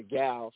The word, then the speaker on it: gyal: the gal, spelled G-Y-A-L, and that's how we pronounce it.